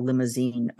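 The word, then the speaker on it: Limousin: So you often hear about Limousine oak, and it's exactly how it sounds, except like the car, except without an E at the end, but it's pronounced in the same way.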